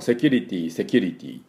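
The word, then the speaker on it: security: ちょっとね、このセキュリティっていうふうに私たち普段使っておりますし、言い慣れておりますが、この発音がちょっと違うかなという感じですね。発音修正やっていきたいと思います。実際の発音はどういう発音かっていうと、セキュリティという発音です。で、まずこのセ、このセじゃなくてシ、これ I ですね、ほとんどね。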